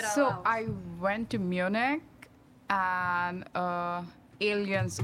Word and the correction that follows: Allianz: Arena, if I'm pronouncing it right. – Allianz. – Okay, like two people correcting me. Allianz. Yeah. So yeah, it's Allianz Arena.